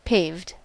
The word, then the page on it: paved: American English